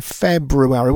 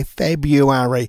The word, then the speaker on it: February: He keeps saying February. Well, it is spelt with an R in the middle of it, and I hate it when people say February because it's February.